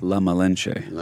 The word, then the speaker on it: La Malinche: La Malinche.